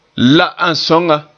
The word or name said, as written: la